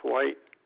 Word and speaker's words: White: Kermit, K-E-R-M-I-T. Last name is White, W-H-I-T-E.